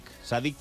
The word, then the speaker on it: Sadiq: Sadiq como el delantero del Valencia. Se escribe con C y con K, es un poco diferente, pero bueno, Sadiq.